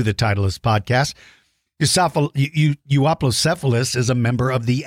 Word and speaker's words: Euoplocephalus: There are different pronunciations. Some of you might pronounce its name Euoplocephalus. Some Euoplocephalus. I pronounce it Euoplocephalus.